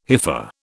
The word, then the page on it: hypha: British English